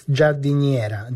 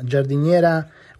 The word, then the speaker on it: Giardiniera: It's Giardiniera. Giardiniera, which could be also the name for a female gardener.